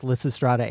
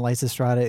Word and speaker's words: Lysistrata: So quick pronunciation note, we have heard that both Lysistrata and Lysistrata is correct from multiple different sources.